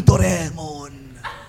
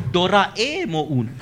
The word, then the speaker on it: Doraemon: Ang Doraemon. – No, it's Doraemon.